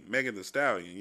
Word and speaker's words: Megan Thee Stallion: Excuse me, Megan Thee Stallion. Yeah, did I say it right? Yeah, okay, cool.